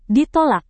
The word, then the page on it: ditolak: Indonesian